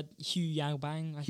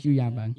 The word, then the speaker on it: Hu Yaobang: I don't really know how to pronounce his name, but I think it's Hu Yaobang, I think. Do you know how to pronounce that? Doing history? – Yeah, Hu Yaobang. – Hu Yaobang.